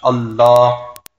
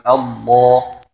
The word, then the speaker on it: Allah: dass erstens das L von Allah ist dunkel. Wir sagen nicht Allah, wir sagen, oder Allah, ja, wir sagen Allah, Allah.